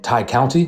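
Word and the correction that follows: Tay Conti: Is that how you said? – Uh, Ty Conti. – Ty Conti. Conti.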